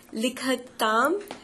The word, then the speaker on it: लिखतु: लिखतु लिखतां लिखन्तु. – स्पष्टतया वक्तव्यमस्ति. येषां मातृभाषा भारतीयभाषा अस्ति तेषामपि दोषः भवति अत्र. प्रादेशिकभाषासु अपि महाप्राणस्य उच्चारणं यथा भवेत् तथा न भवति. लिखतु ख ख लिखतु.